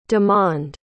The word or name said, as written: demond